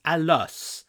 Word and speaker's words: Alus: Alice. I keep calling him Alice, but it's Alus, like Atlas without the T. – I don't know. I would have pronounced it Arus.